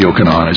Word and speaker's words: Yochanan: Jacob and Yochanan, or Yaakov and Yochanan, as it would have been pronounced in Hebrew.